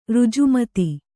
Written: ಋಜುಮತಿ

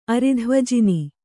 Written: ಅರಿಧ್ವಜಿನಿ

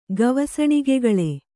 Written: ಗವಸಣಿಗೆಗಳೆ

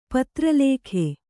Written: ಪತ್ರಲೇಖೆ

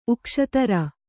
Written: ಉಕ್ಷತರ

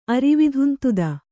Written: ಅರಿವಿಧುಂತುದ

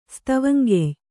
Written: ಸ್ತವಂಗೆಯ್

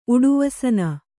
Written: ಉಡುವಸನ

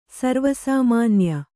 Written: ಸರ್ವೇಸಾಮಾನ್ಯ

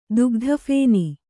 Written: ದುಗ್ಧಫೇನ

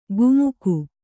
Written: ಕುಮುಕು

